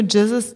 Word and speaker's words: Gzuz: Rana, weißt du, wofür Jesus steht? Mhm. Ghetto-Zeugs unzensiert. Total innovativ, oder? Und dann soll das aber so Jesus-mäßig ausgesprochen werden. – Aber es wird GZUZ geschrieben.